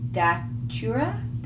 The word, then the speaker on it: datura: Okay, middle notes, Chinese jasmine Simbach, however we say it, orange blossom, peony, and datura? – Yeah, that's how you say it.